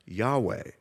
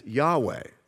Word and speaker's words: Yahweh: In Hebrew, his name is spelled with four consonants, YHWH, which is pronounced Yahweh. In our English Bibles, whenever you see the word Lord in all caps, that's Yahweh.